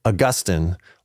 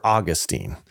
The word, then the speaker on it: Augustine: So there is no best pronunciation. So there'll be scholars who will say, well, it's Augustine or Augustine, but both are correct. So don't let someone corner you and say you're saying it wrong, because there's no standardized way. So it's Augustine or Augustine. Either are fine.